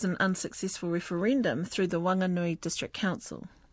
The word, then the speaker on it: Whanganui: Although adding the letter H won't change the pronunciation of Whanganui because it is silent, iwi believe that the proper name should be reinstated.